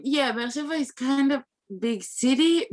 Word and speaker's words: Be'er Sheva: So like Be'er Sheva. – Be'er Sheva. Okay. Yeah. – Okay. Good sorry, question. I'm saying it wrong. – No. Yeah, very good.